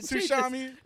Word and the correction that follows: Tsushima: It actually is Tsushima.